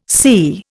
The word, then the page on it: psi: Indian